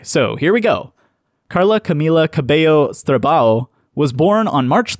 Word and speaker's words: Camila Cabello: So, it's Camila Cabello, not Camila Cabello or Camila Cabello, Camila Cabello.